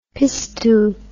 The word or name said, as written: pistou